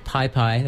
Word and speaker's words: PYPY: Before that, we heard music from an album that just came out in September by a newer band called Pi Pi. That's spelled P-Y-P-Y, no space.